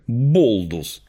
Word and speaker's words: Baldur's: Он, кстати, в оригинале произносится как «Болдус».